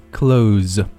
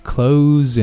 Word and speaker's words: close: 注意这里读作 z, close, 不是 s 啊，close enough.下面呢，首先我们来进行分句的跟读练习。首先，第一句，I can give you the numbers, but you can't go public with it.